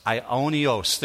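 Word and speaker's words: aionios: The word eternal means this, and it's pronounced ienos. It's a difficult one. I'll take my Greek hat off. I-I-A-I-O-N-I-O-S. Ionios. There we go. So the shortened version of it is Ion, A-I-O-N.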